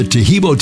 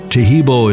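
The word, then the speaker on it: Taheebo: Tehibo is spelled T-A-H-E-E-B, like boy, O, then the word tea, and then the word club.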